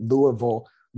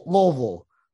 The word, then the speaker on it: Louisville: Yeah, I had someone recently tell me that Louisville and Louisville are incorrect. – It's almost like you have to say it like you have like a mouthful of peanut butter, like peanut butter stuck on the roof of your mouth.